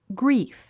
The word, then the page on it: grief: ɡríːf; グリーフ